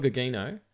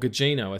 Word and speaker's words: Gugino: Or Carla Gugino. – Mm-hmm. – Or Gugino, I think it is. I've been pronouncing it wrong, sorry.